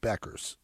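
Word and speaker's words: Bueckers: The U is silent. It's Paige Beckers.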